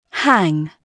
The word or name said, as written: hang